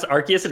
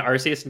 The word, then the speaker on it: Arceus: So it's pronounced Arceus. It's pronounced Arceus in English and Arceus in Japanese.